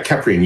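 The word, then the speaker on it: Kheprian: Yeah, Keprian, you've got it correct. And a lot of people pronounce it wrong. And Egyptologists have even said that I might be pronouncing it wrong. So it's all good. We'll go with Keprian, and that's going to be our common pronunciation tonight.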